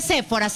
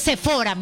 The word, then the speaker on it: Sephora: No se dice Sephora, se dice Sephora, mijito.